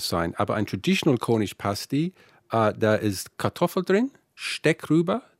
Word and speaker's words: pasty: Fast jeder, der das liest, sagt natürlich, Tasty Pasty, ja, lustig. Und dann wird er erstmal ordentlich auf die korrekte Aussprache verwiesen. Denn erst, wenn du richtig Pasty sagen kannst, Pasty, Pasty, dann kannst du Daniel und Paul auch fragen, was das überhaupt ist.